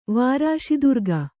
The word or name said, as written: ವಾರಾಶಿದುರ್ಗ